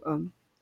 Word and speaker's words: Haum: Et en fait, euh, ouais, home, ça m'est venu un peu naturellement en mélange de home, la maison, et home, A-U-M. Et du coup, home.